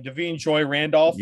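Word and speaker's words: Da'Vine Joy Randolph: Yeah, it's I think this is how you say her name. Devine Joy Randolph. – Yes. Right? Yep.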